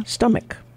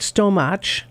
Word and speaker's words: stomach: A lot of people understandably pronounce stow like slow and imagine the ch sound be like the first sound in cheese. They're not. It's not stomach. It's not stomach. It's stomach.